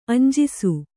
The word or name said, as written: ಅಂಜಿಸು